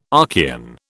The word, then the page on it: Archean: British English